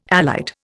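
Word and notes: élite: Indian

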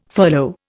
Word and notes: furlough: Indian

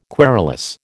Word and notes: querulous: American English

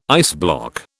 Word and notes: iceblock: British English

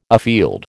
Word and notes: afield: American English

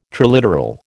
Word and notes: triliteral: American English

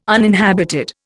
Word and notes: uninhabited: Indian

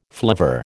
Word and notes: flivver: American English

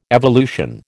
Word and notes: evolution: American English

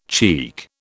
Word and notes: cheek: British English

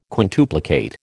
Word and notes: quintuplicate: American English

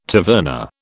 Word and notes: taverna: British English

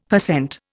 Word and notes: per cent: Indian

